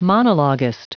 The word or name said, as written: monologuist